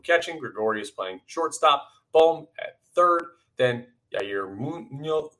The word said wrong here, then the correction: Yairo Munoz: let's say his name right. Yairo Munoz. I keep wanting to say it like Frankie Muniz from uh, from Malcolm in the Middle.